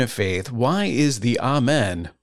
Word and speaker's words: Amen: By the way, when you see that word, A M E N, the way to pronounce it is Amen. You'll often hear people say amen, which is common in American English, but amen is closer to the right sound, so give it a try.